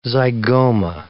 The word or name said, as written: zygoma